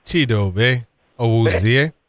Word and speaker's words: Chidobe Awuzie: How do I pronounce the name? – Oh, Chidobe Awuzie. – Awuzie. Him, and then you got McCrary, who's a fighter.